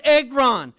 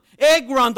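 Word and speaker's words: egron (ἔργον): The word work there in the Greek is pronounced egron. Egron, the word work.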